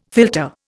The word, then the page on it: filter: Indian